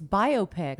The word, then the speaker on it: biopic: B-I-O-P-I-C. – One more time, because I was writing something. – B-I-O-P-I-C. I'll give you the definition. – Biopic? – Right. That's what I thought. – Biopic. – It's biopic.